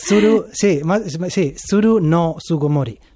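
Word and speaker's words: Tsuru no Sugomori: Se llama Tsugo no Tsugomori. No sé si lo he pronunciado bien. – Tsuru no Tsugomori.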